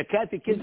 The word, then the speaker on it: catechism: The catechism? The catechism. – The catechism, if, what? – Catechism, you said it right.